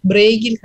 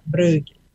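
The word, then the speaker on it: Bruegel: Вот, мы его знаем как Брейгель, хотя иногда произносится Брюгель, вот, по-фламандски.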